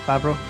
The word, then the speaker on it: Favreau: That's how you say it, Favreau? – Yep, that's how you say it, well done.